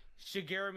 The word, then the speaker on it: Shigeru: So, yeah, they the creator, Singru, is that how you say it? – Shigeru Miyamoto. – Yeah, there you go. Shigeru, yeah, exactly what he said.